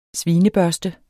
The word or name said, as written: svinebørste